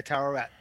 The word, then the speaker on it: Tawaret: And almost at the same time, Layla bonds or is the avatar of, how do you pronounce it? Tawaret? – Yeah, Tawaret.